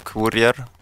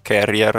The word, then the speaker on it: Courier: Sì, si chiama Onboard Courier. Carrier, a seconda di da dove arriva la pronuncia inglese che si adotta, sì.